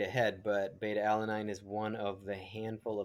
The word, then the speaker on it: beta-alanine: Beta alanine? Did I say that correctly? – Yes, beta-alanine. It is,